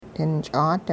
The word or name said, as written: dinch'aat